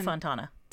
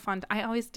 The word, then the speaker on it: Fontana: Santino Fontana. – Santino Fontana. – Santini. – The O's and the A's. – Santani. – Santino Fantano. – No, it's Fontana, I think. – Fontana.